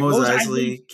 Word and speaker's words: Mos Eisley: I never get the name right. – Mos Eisley Cantina.